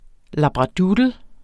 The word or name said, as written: labradoodle